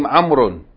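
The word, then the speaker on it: amrun (عمرو): This particular name, amrun, is written with a waw, which is not pronounced.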